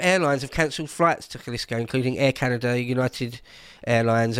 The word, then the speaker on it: Jalisco: Obviously, it's written Jalisco, but I think it's pronounced Jalisco.